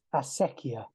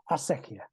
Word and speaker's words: Acequia: So it's a Spanish word. It's Acequia. And it's written in the description of the project, actually. It's derived from the Arabic word, which means water carrier. So, Acequia.